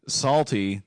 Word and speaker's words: Psalty: Psalty, that's P-S-A-L-T-Y, the Singing Songbook. Psalty, pronounced like Psalms and Psalty in parentheses.